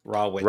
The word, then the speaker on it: Rawitch: Close, like an uncooked witch. Raw witch.